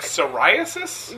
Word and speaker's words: psoriasis: Silent P's. Why? What? Are we talking about in words? – Yes. Yeah, that's a good topic. – Like psoriasis? – Yes. Like, what's the point? – Or psychology?